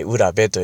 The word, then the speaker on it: 浦部: だから、浦部さんとかね、えー、裏に部屋のえー、兵と書いて浦部と読みますが、浦部さんというのはもうそのまんま昔、えー、そういったお仕事をされていた、あ家系だということがわかるわけですね。